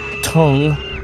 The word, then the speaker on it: Thung: Tung, which looks like thung, but it's pronounced tung, Mulam, which is, according to Thai people, that's their version of country music.